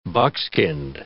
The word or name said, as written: buckskinned